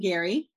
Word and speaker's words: Garee: You can find me on Facebook at Jane Garee. And my last name is spelled G-A-R-E-E.